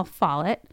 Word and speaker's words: Follett: Yeah, it's Barbara Newhall Follett.